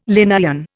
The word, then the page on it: Linnaean: Indian